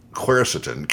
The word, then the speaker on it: quercetin: The other one, I guess you pronounce this quercetin, Q-U-E-R-C-E-T-I-N. It's a plant chemical that helps to stabilize mast cells that release substances, including histamine, that contribute to inflammation and your allergies.